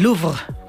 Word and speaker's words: Louvre: En este mes de octubre, por ejemplo, en París, en el Louvre, que se dice así como lo dicen los parisienses o los franceses, ha expuesto, lo está haciendo también en otras ciudades mundiales como son Quebec en Canadá o será en Berlín.